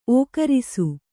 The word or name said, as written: ಒಕ್ಕರಿಸು